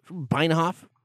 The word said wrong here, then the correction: Benioff: I think it's Benioff.